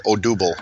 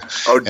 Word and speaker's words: Odubel: And I always pronounce his name wrong, and I always try to practice it, and I still get it wrong. Odubel, right? Odubel. – Odubel.